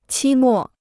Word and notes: 期末: qī mò

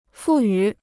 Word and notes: 赋予: fù yǔ

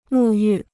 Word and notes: 沐浴: mù yù

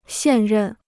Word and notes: 现任: xiàn rèn

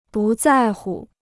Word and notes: 不在乎: bù zài hu

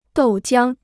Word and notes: 豆浆: dòu jiāng

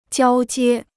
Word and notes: 交接: jiāo jiē

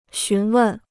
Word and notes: 询问: xún wèn

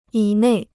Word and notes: 以内: yǐ nèi

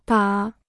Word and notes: 打: dá

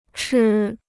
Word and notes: 吃: chī

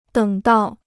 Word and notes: 等到: děng dào

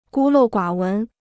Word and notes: 孤陋寡闻: gū lòu guǎ wén

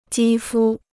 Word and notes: 肌肤: jī fū

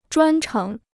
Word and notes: 专程: zhuān chéng

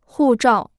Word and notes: 护照: hù zhào

